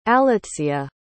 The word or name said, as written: alytzia